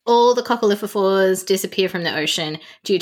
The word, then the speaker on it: coccolithophores: All the coccolithophores, did I say that right? Coccolithophores? – Yes, you did.